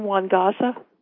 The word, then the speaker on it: Nwangaza: And Afia, is your last name Juan Gaza? – Yes, that's okay. – Correct. – Would everybody please note that, the correct pronunciation? Thank you. – Isn't it spelled N-A-G-A-Z-A? – No, the N is silent, correct? – That's correct. N-W-A-N-G-A-Z-A.